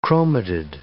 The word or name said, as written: chromatid